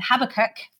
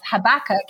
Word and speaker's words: Habakkuk: I grew up saying Habakkuk. I mean, it's Habakkuk.